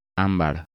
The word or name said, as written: Ambar